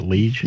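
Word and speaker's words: Liège: Is that how you pronounce that, Liege? – Yeah.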